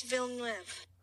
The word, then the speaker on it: Villeneuve: According to YouTube, this is how you say it. – Dennis Villeneuve. – So there he goes.